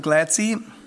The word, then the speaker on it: Glatze: Der schreibt sich, wenn Sie das mal googeln wollen, Michael Glatze, aber so spricht sich das bestimmt nicht aus, Michael Glatzy in New York.